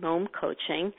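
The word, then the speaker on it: Mome Coaching: It's spelled M-O-M-E, and it rhymes with the word home and Mom Coaching.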